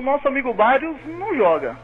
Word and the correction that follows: Barrios: O Bários, pô, falei certo. – É Barrios. – Ah, tá bom, desculpa aí. O Barrios.